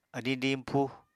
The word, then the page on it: adiñ diñpúu: Apatani